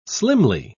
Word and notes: slimly: British English

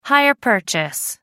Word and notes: hire purchase: American English

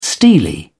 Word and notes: steely: British English

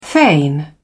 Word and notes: feign: British English

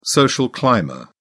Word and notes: social climber: British English

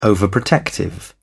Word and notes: overprotective: British English

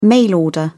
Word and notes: mail order: British English

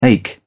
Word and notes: ache: British English

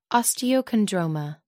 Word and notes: osteochondroma: American English

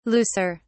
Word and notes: looser: British English

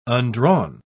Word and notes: undrawn: British English

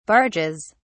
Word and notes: barges: British English

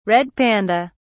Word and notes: red panda: British English

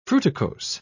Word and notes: fruticose: British English

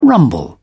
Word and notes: rumble: British English

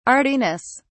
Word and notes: artiness: British English

